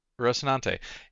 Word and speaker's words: Rocinante: Rocinante.